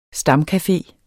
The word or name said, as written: STAMCAFEER